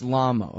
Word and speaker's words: Lamo: I wanted to ask you about the testimony of Adrian Lamo, because, or is it Lamo? I don't know. If it really is Lamo, I don't want to have been mispronouncing it this whole time, and let him go ahead and have that. But, I think it's Lamo. – It's Lamo. Okay.